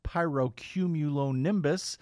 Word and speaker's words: pyrocumulonimbus: something called a pyrocumulonimbus.